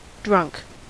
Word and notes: drunk: American English

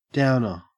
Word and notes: downer: Australian English

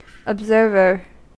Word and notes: observer: American English

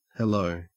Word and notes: hello: Australian English

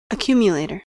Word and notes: accumulator: American English

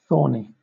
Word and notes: thorny: British English

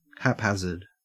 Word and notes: haphazard: Australian English

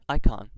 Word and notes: icon: American English

